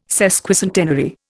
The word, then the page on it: sesquicentenary: Indian